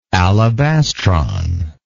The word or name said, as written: alabastron